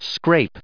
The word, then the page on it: scrape: American English